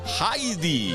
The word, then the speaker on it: Heidi: Heidi, vaya desilusión, resulta que se dice así, Heidi.